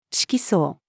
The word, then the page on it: 色相: female